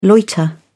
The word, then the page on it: loiter: British English